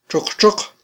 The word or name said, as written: txoqtxoq